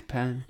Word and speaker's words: Penne: Yeah, Penne. – The official pronunciation.